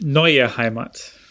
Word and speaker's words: Neue Heimat: Was that good pronunciation? – It was rubbish. Neue Heimat. – Neue Heimat. Yes. Is that good? – Put a bit of oomph in it.